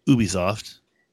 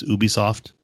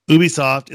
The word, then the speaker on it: Ubisoft: That's how you say it. Ubisoft. You know that, right, Tim? It's not Ubisoft. It's Ubisoft. – Tomato, tomato. – Ubisoft is working on a new Star Wars open world game.